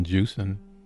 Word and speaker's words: Jewison: Norman Jewison, is it pronounced? – Mm-hmm.